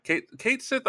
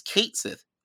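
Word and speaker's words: Cait Sith: I always call it Cat Sith because I got the name wrong every time. Later, someone, I think Liz corrected me, is like, it's Kate Sith.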